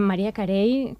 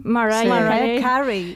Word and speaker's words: Mariah Carey: Parlem, per exemple, de Whitney Houston, Luciano Pavarotti, Maria Carey... Com se pronuncia bé, Maria? – Mariah Carey. – Però sí que és veritat que sempre li han dit Maria Carey.